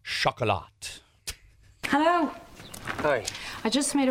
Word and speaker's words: Chocolat: I only remember because I, we were making fun of it because you had to pronounce it chocolat. – Yeah. You know, chocolat. chocolate in French.